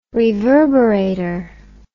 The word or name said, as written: reverberator